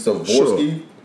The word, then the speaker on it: Swarovski: Swarovski? – Swarovski.